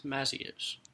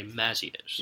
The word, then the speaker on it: Mazzios: How is the word M-A-Z-Z-I-O-S pronounced? – It's Mazios. – Mazios is the correct answer. – The short A, Mazios.